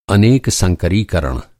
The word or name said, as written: संकरीकरण